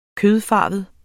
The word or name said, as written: kødfarvet